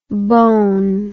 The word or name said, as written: bohn